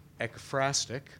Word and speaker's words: ekphrastic: And I picked a poem because that's ekphrastic, though I want to put an extra syllable in that. I want to say ekphrastic.